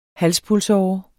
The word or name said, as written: halspulsåre